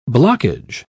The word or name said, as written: blockage